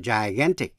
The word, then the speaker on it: gigantic: Biệt lệ, chữ get, G-E-T, G đọc là G. Hay là chữ gecko, gecko là con thằn lằn. Thường phát âm là J nếu chữ đó, chữ G đó theo sau bởi I. Thí dụ như gigantic, vô cùng lớn, gigantic, gigantic.